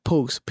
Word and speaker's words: Pooks: Boy, B-O-Y, Pooks, P-O-O-K-S.